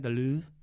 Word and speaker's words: Louvre: We're not going from the high school art show to the Louvre on Sunday. – This ain't the Art Basel. – That's how you pronounce it, right? The Louvre? – Yeah.